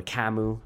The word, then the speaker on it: Camus: The absurdity of life is a perspective that goes back to the old existentialists, guys like Sartre and Albert Camus, or Camus, I don't know, it depends who you're talking to, right? The guy was French, so who knows? but I believe the more usual pronunciation is Albert Camus.